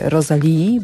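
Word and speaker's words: Rosalía: No, nic nie odda tych emocji, jakie towarzyszyły mi na festiwalu Primavera, kiedy stałam w gigantycznym tłumie przed, w trakcie i po koncercie Rosalii, bo tak się wymawia imię tej artystki.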